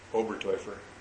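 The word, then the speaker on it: Oberteufer: Obertäufer. – Obertäufer. A German name? – T-E-U is boy. Toy.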